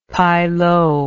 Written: pailoo

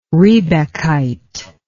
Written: riebeckite

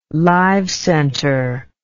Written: live center

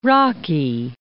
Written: Rocky Mount

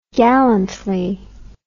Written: gallantly

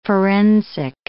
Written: forensic

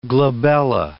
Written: glabella

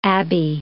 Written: abbey

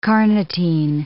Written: carnitine

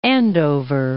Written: Andover

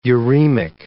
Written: uremic

